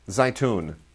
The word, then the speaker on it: Zaytoun: Zaytown. – Zaytoun. – Oh, it's funny because they say it in the movie, but I still pronounce it wrong.